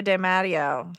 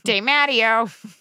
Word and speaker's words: de Matteo: Trea De Matteo. – Matteo. You're so white. De Matteo.